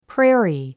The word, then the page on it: prairie: préəri; プレェアリィ